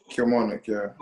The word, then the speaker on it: Kilmarnock: Um, did I did I say the name right as well? Kilmarnock, yeah. Kilmarnock, okay.